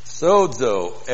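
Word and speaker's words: sozo (σῴζω): Sozo, S long O, Z long O, sozo.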